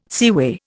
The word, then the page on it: seaway: Indian